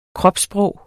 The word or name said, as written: kropssprog